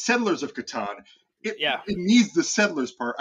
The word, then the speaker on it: Catan: There's, I know you asked people whether or not you pronounce it Catan or I can't even say it the other way. – That's right. It's Catan.